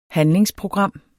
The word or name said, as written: handlingsprogram